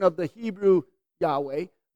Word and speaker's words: Yahweh: The name was pronounced Yahweh.